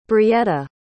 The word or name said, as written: brietta